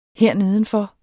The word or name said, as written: hernedenfor